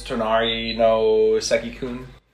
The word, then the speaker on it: Tonari no Seki-kun: As the title will tell you, it's My Neighbor Seki, or how do you say that in Japanese? Tonari no Seki-kun. – Okay.